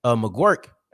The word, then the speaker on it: McGuirk: Now you look at offensive coordinator McGuirk. I had to make sure I put the pronunciation in here because that's not what it looks like, but that's what it is.